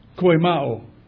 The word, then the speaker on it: koimao: It's koimao. It's spelled K-O-I-M-A-O, koimao.